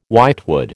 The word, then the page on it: whitewood: American English